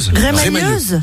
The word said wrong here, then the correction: remailleuse: Remailleuse. – On dit, ça se prononce pas comme ça? Remailleuse? – Remailleuse, oui. – Ah, remailleuse, pardon.